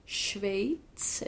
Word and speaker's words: Schweiz: Vi såg ju vad som hände när hon kastades in mot Schweiz. Är det så man säger det? – Så säger man det.